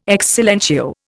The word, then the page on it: ex silentio: Indian